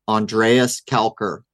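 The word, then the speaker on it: Andreas Kalcker: The man's name is Dr. Andreas Kalker.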